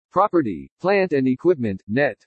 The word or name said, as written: PROPERTY PLANT AND EQUIPMENT NET